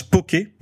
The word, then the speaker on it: poke: donc poke en hawaïen, qui se prononce poke, donc c'est bien ce que je disais tout à l'heure, poke, signifie couper en morceaux.